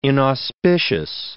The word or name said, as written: inauspicious